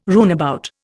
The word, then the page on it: runabout: Indian